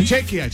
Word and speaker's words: Chequia: O Chequia. Chequia. – ¿Cómo se dice? ¿Cuál es? – Chequia. República Checa, yo creo. – Pero Chequia, no, así aparece.